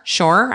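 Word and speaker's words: Szohr: How do you say her name? S-Z-O-R, Shore, I think, from the reboot of 90210.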